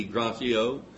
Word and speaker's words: Gratiot: We were talking about the name of this prison before service, and evidently it's a French word, so G-R-A-T-I-O-T would be Gratiot, but Brother Henry and I said a true Southerner would just say Gratiot.